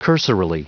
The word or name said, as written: cursorily